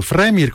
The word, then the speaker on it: fremir: Fremir come nasce come nome? – In realtà viene dal verbo fremir, che è un verbo portoghese, ma anche francese se vogliamo, però lo sto pronunciando alla portoghese, e vuol dire fremere.